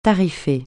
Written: tarifer

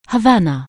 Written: Havana

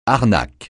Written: arnaque

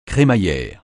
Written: crémaillère

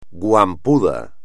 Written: guampudo

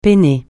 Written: peiner

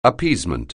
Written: appeasement

